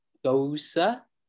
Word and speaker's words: Gose: Gose? – That's how you pronounce it. But it's a gooseberry gose. – Oh, okay. Okay, I like the can. – German. Yeah, so you know that the pronounced Gose, it's the German-style beer from Goslar, Germany.